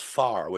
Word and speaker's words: fear: And the word pharaoh comes from the Irish word fear, it's spelled, but it's pronounced far, which means man.